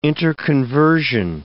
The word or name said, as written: interconversion